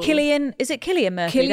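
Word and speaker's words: Cillian Murphy: Killian Murphy, that's how you say it, has a holiday house in my hometown.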